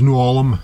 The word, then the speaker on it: New Ulm: How do you pronounce the town that you're in? – It's an old German town. It's two words, New Ulm, N-E-W, then U-L-M.